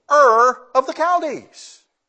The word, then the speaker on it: Ur: The only way I know how to pronounce it is Ur. I guess that's right.